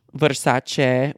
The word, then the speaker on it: Versace: I pronounced that right. Thank you very much. Versace.